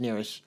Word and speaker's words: Nearest: Nearis Green, it's pronounced Nearis, was an enslaved African American distiller.